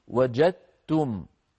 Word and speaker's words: وجدتم: فيصير النطق وجدتم هكذا بتاء مشددة وجدتم، لا يبقى للدال أثر في النطق أبدا.